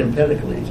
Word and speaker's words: Empedocles: How do you pronounce it? – I think it's Empedocles.